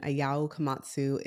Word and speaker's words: Ayao Komatsu: So he's out as team principal and Ayao Komatsu is now the team principal. So he's... – Hey, props for saying his name right. – I hope I said it right. Yeah. – I think you did.